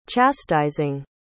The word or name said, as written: chastising